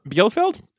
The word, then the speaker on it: Bielefeld: So, Biofield, maybe, is actually how you say it? Yeah. Biofield. – Well, yeah. – I don't know how to read the pronunciation guide I gave myself. – Bielefeld.